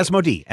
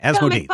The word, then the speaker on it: Asmodee: Asmodee. Asmodee. That's how we do it. – Okay, great. Comic publisher SourcePoint Press and game developer Asmodee Entertainment have signed a licensing agreement that will see three Asmodee games get the comic book adaptation treatment.